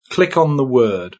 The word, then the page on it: click: British English